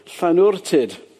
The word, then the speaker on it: Llanwrtyd: Not many of you could probably pronounce that, but Llanwrtyd. And in the 19th century, they tried to make it into a spa, and so they added the silly name after it, Wells. So it's sometimes known as Llanwrtyd Wells.